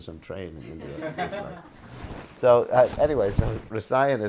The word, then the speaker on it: rasayana: And I say rasayana. Kata. Would you say kat? Yeah, you say kat. Kata. Rasayana. Oh, because there's a long A there. Yeah, you only get rid of the short A's.